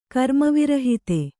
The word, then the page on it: ಕರ್ಮವಿರಹಿತೆ: karmavirahite